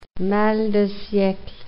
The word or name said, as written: mal de siecle